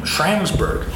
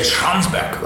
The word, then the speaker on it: Schramsberg: So the original founders, frankly, the name, Schramsberg, or some would say Schramsberg, that would be more of a German pronunciation, dates back to that earlier era.